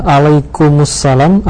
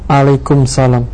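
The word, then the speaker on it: alaikumussalam (عليكم السلام): Alaikumussalam atau alaikumsalam? Syukran. – Demikian, Ustaz. Nah, kalau assalam, kalau huruf terakhirnya assalam diberi alif lam, maka yang benar adalah alaikumussalam, karena mim mati ketemu dengan alif lam yang juga mati.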